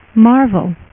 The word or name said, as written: Marvel